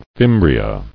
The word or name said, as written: Fimbriae